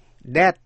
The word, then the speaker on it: debt: Debt là nợ, D-E-B-T, B cũng không phát âm. Limb, limb, L-I-M-B, nghĩa là cành cây hay là chân hay tay.